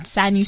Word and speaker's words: Sanyu: Okay, so you can either type my name out, Sanyu Sematimba, so double S-A-N-Y-U, S-E-M-A-T-I-M-B-A.